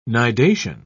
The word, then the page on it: nidation: British English